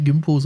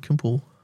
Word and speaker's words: Gimpo: Deswegen auch nicht Gimpo, sondern Kimpo. – Ah, okay.